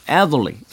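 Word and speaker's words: Adderley: 저희 예전에 음악 들을 때는 캐논볼 애들리라고 하면은 그 선배들이 no, no, no, 애들리, 애들리 이렇게 애들이 발음에 특히 힘줘서 하던 네.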